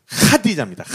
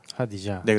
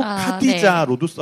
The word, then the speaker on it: 하디자: 네, 그래서 그 누님의 이름이 이제 이게 발음이 우리한테는 없는 발음이어가지고 하디자입니다. 하디자. – 하디자. – 네, 그래서 네. 우리 그 한글로는 하디자로도 쓰고 카디자로도 네. 써요.